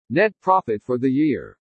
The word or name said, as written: NET PROFIT FOR THE YEAR